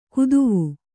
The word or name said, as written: ಕದವೆ